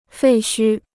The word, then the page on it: 废墟: fèi xū